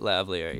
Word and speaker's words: lavalier: But that is something I just have gotten into because I want to get a little Lamphere mic to do some like... – Oh, Lavalier. – How do you say it? Lavalier, yeah.